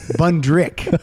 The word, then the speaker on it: Bundrick: Bundick. – Yeah, Bundick is the problem. – Oh, I'm sorry. I'm saying it wrong. I'm saying his name wrong. It's John Rabbit. Bundrick. It's not Bundick.